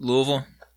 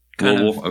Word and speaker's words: Louisville: How do you say it? – Just Louisville. – Louisville? Okay. Yeah. – Yeah, the correct way.